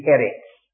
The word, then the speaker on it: Eretz: And the word earth in the Hebrew language is Eretz, and we spell it E-R-E-T-Z, Eretz. Now the word for oppressor is Aritz, A-R-I-T-Z.